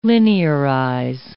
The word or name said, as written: linearize